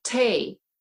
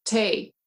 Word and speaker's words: Tae: Oh, it's really easy. Tay. – Okay. So think Tay. Cafe Tay. So it's okay. T-A-E accent aigu.